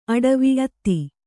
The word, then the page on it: ಅಟವಿ: Kannada